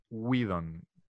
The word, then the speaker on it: Whedon: Entonces contrataron a Joss Widow, Widow. No sé qué forma se pronuncia. Joss Whedon. Sí, creo que es Whedon.